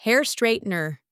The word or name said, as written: hair straightener